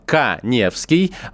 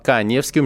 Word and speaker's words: Каневский: Также доктору Мясникову и, собственно, вам, всем моим слушателям, я рекомендую подписаться на мой инстаграм Эдуард Каневский. У меня фамилия пишется через А. Эдуард Каневский.